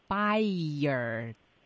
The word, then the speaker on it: expired: 对，分成两个音节。 – 哎，叫 expired。 – 对，expired。 – expired。